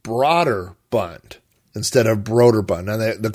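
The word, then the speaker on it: Brøderbund: And I looked up a YouTube interview with one of the founders of the company, and he pronounced it Broderbund, but he doesn't say it long like I said Broder. He said Broder, like Broderbund, almost with the emphasis being on bund, like Broderbund, Broderbund.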